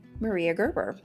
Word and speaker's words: Maria Gerber: And we have with us this month Dr. Laura Chevalier-Beer, and she is bringing us the story of Maria Gerber. Did I say that right? – You did.